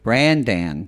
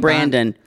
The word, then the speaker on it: Brandan: Brandan. – Brandan, because it's spelled that way. I don't know. I mean, when people keep saying Brandon, Brandan.